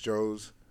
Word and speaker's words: Joez: Some people don't even know how to, it's Joes McFly, right? You know it's Joes. Some people say some weird stuff and they pronounce Joez, Josie. There's one dude said Joey Diaz. I'm like, where'd that come from? I'm not even like anywhere near that.